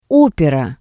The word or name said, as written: opera